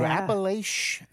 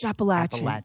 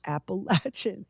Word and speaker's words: Appalachian: I hate when people go Appalachian. – It's Appalachian. – Appalachian. – Here's an apple and a latch. Appalachian.